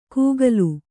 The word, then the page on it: ಕೂಗಲು: kūgalu; Kannada